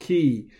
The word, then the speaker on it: chi: Now, many people are familiar with the word chi, and sometimes it's pronounced ki.